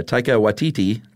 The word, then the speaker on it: Taika Waititi: Written and directed by Taika Waititi. Did I pronounce that right? – Yes, I believe so.